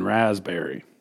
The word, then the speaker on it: raspberry: Nobody ever knows about that P in raspberry. – Are you supposed to just enunciate it? Like really? – I think it's a silent P.